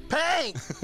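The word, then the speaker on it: P!nk: I hope today somebody comes up to you and points at you and makes you yell out, Pink! Just from, we're yelling it because her name is spelled P exclamation port. Port?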